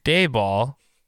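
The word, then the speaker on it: Daboll: And I'm pretty sure it's pronounced Dayball, but I agree with you.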